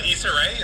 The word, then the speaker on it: Issa Rae: So I say Aiza, but I could also hear it pronounced Issa. – Okay. – Issa Rae? Is that who we're talking about? – Issa Rae. Yeah. – That's Issa Rae. Issa Rae. Okay, so I was right.